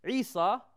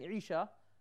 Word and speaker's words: Isa: And more specifically, Isa, since the northern Palestinian Jews pronounced the letter Sheen as seen, meaning instead of saying Isha, they say Isa.